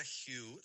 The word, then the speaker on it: Hu: La palabra Hu se escribe H-U, se pronuncia J-I-U, suena así. Hu.